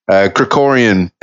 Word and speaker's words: Krikorian: Serge Krikorian, and I'm glad that you pronounced it because I was working on the enunciation, the word Krikorian, and I hope I'm pronouncing it properly now.